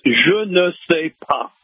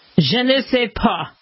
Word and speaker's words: je ne sais pas: And the correct pronunciation of the sentence is, je ne sais pas. – Je ne sais pas. – Je ne sais pas.